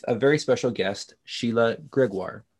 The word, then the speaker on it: Gregoire: Am I saying that right, Gregoire? – Yeah, that was pretty good. Yeah. – Okay, good. I wasn't sure if you'd say it Gregoire in the French, so, but Gregoire. Perfect.